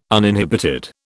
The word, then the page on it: uninhibited: British English